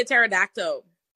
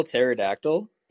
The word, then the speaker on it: pterodactyl: Why would I be a pterodactyl? – Is that actually how you spell pterodactyl? – Yeah, correct. – It's a silent P.